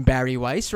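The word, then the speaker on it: Bari Weiss: This piece by, I think you pronounce her name Barry Weiss, right? – Number one sweetie, Barry Weiss.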